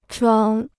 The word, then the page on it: 床: chuáng